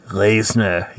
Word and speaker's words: Lesnar: Bork Lesnar. Am I saying that right? – I think so. – I think it's Bork Lesnar. – Lesnar, yes. Bork Lesnar.